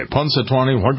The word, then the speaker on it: Punxsutawney: People say Punxsutawney. Punx, P-U-N-X. Think of the two Manning brothers. They're punks, right?